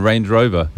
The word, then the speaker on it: Range Rover: Buena pronunciación, señora. Lo dijo muy bien, Range Rover.